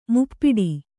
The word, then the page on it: ಮುಪ್ಪಟ್ಟೆ: Kannada